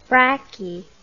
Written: brachy

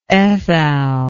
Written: ethal

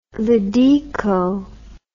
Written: Ladeco

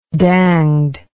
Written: danged

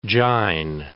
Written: Jain

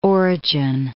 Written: Origen

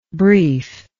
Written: briefness

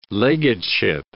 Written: legateship

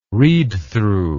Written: readthrough

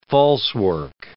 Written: falsework

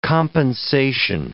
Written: compensation

